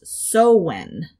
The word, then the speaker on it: Samhain: Samhain is how I would pronounce it. But this is in Gaelic. In Gaelic, it's pronounced Sowen.